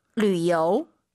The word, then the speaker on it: 旅游: 旅游。 – 유땡땡, 류. 류이라 발음합니다. 삼성이성 아아 리요.